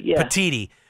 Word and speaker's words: Petitti: How do you pronounce his name? I don't even know. – Petitti.